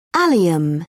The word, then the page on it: allium: British English